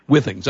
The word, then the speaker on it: Withings: So tell us about it's pronounced Withings, correct? – Yes. – Withings. Okay. So Withings, Withings, either way.